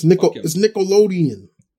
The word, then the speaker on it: Nickelodeon: He says Nickelodeon. Nickelodeon. It's Nickelodeon. – You're dropping a D there, pal.